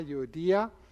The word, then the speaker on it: Euodia: I entreat Euodia is how a lot of people might pronounce it. But really it's Yodi. The accent is on the I, Euodia.